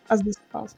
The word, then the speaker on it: As Dusk Falls: Mas eu consegui um tempinho para jogar As Dusk Falls. Uhum. Falei certo? É As Dusk Falls. – Sim.